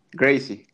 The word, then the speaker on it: Gracie: Y, eso lo de la escuela de Brasil en Jiu-Jitsu, Gracie. ¿Gracie? – Gracie, Gracie.